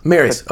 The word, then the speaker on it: Mares: Justin, did I say your name right? – How do you? Close, Maris. – Maris. Okay, cool. Justin Maris.